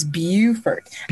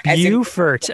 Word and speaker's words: Beaufort: Well, if you're in North Carolina, it's Beaufort, but when you're down here in South Carolina, it's Beaufort. – Beaufort, okay.